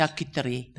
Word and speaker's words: charcuterie: Have you got to say it like that? Yes. Charcuterie.